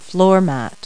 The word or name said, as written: floor mat